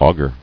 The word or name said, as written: Auger